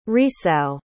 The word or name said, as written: resow